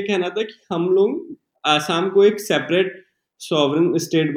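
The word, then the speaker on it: असम (অসম): आसाम मतलब आसाम को लोग थोड़ा वहाँ पे लोग क्या आ को ओ बोलते हैं ओ का प्रोनाउंसिएशन आता है तो असम बोलते हैं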